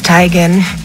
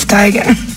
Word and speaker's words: Teigen: And her mom confirmed that we've been saying her name wrong this whole time. – I'm tired of living this lie. It's Tigan. Isn't it, Mom? – Yep. – I told you, it's Tigan.